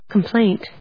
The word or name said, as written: complaint